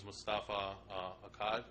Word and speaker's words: Mustafa Akkad: But also, I think it was Mustafa Akkad, that's how you say his name? – Yep.